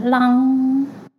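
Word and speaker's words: LAM: 好，那它的发音是 long long long。 L A N 哦，long 好，所以我们待会呢，在冥想之前呢，我们就可以先做这个手势跟做一个发音的调频来进入冥想。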